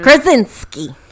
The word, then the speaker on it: Krasinski: A, I'm not a huge fan of of John Krasinski or however you say his name. Is. – Krasinski.